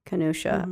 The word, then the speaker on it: Kenosha: Kenosha.